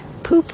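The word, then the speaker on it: Poope: I'm Jeremiah Poop, your friendly local Boston Colonial tour guide at your service. – It's okay. Poop with an E. – With an E. E is silent.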